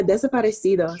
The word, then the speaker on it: Desperacitos: So the track that we heard before we opened up this long speaking segment, The Left Is Right by I'm bad with pronouncing stuff like this. Desperacitos? – Yeah, Desperacitos.